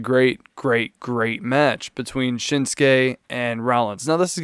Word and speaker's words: Shinsuke: When I first found out who Shinsuke Nakamura was, the way that I pronounced his name, probably like a lot of Americans, was Shinzuki. Shinzuki Nakamura.